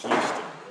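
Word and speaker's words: Heuston: Yeah, but they then don't pronounce the H, so it becomes Houston.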